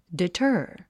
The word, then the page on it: deter: ditə́ː r; ディタァ